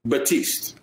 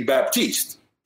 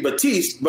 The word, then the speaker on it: Baptiste: But that's in French. People in English, they would normally pronounce the P, which would be Baptiste. Mm-hmm. So when they asked me, they're like, hey, how would you say it? I said, well, I'd say with the P, I'd say Baptiste.